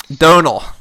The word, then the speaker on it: Domhnall: Well, it's also, like, that actor, how do you pronounce it? Domhnall Gleeson? – Donal.